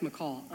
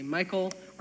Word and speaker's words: Michal: I'm gonna pronounce it Michal because it's my little sister's name, and that's how we pronounce it. I know some of you guys will say Michael or Mikael or Michal, but we call her Michal, so I can't undo my brain.